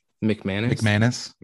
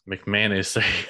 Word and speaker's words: McManus: How do you say his name? Mac Manis. McManus. McManus. McManus.